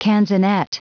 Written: canzonet

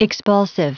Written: expulsive

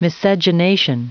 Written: miscegenation

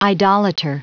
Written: idolater

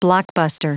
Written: blockbuster

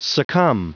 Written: succumb

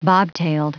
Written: bobtailed

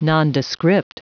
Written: nondescript

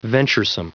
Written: venturesome

